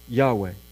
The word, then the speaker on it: Yahweh: So this is why I pronounce the name of God, Yahweh.